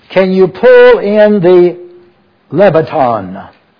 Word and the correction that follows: Leviathan: If your translation has that word, it's spelled L-E-V-I-A-T-H-A-N. Leviathan is the way you pronounce that.